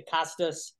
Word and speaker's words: Kostos: Follow Nick on Twitter at the Kostos.